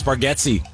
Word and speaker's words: Bargatze: Hey, are you sure you're pronouncing his name right? – Yeah, it's Bargatze.